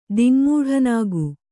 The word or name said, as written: ದಿಙ್ಮೂಢನಾಗು